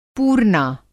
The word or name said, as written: पूरना